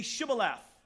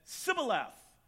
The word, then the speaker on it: Shibboleth: If he said, no, they would say to him, say Shibboleth. And he said, Sibboleth, because he couldn't frame to pronounce it right.